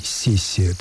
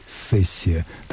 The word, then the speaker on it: сессия: Слово «сессия» произносится двояко. Современный орфоэпический словарь русского языка разрешает как мягкий «си» в первом слоге «сессия», так и твердый «сессия».